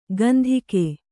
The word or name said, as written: ಕಂತಿಕೆ